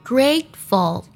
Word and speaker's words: grateful: 这个单词 grateful 在读的时候要注意把 t 的音呢做一个不完全爆破，ful 不能加儿化音。 Grateful 要读作 grateful。